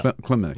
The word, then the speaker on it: Kilimnik: Um, Mueller, uh, uh, the uh, team yesterday admitted that this guy Klimnik, is that how you pronounce his Klimnik. name? Yeah. Kilimnik.